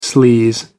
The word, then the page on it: sleaze: American English